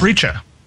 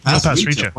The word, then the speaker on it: Pasricha: Just found out that I'm on the guest list, and our friend Neil, I get his name wrong every time. It's Pasricha. – Pasricha, 1,000 awesome things, is going to be a guest speaker.